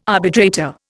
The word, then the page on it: arbitrator: Indian